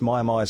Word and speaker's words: Mia Mia: Maya Maya is spelled M-I-A-M-I-A.